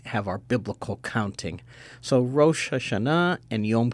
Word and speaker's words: Rosh Hashanah: Now, I'm probably not saying it right. I'm saying it like a wasp, Rosh Hashanah. You say it for us. – Yes, Rosh Hashanah.